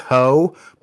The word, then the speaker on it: Heaux: There's a song called These Heaux, but it's spelled H E A U X, like it's French.